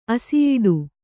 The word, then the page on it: ಅಸಿಯಿಡು: kannada